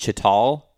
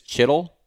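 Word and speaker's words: chital: I thought it was pronounced chittal, but it's chittle. – Chittle. – That's how you guys pronounce it.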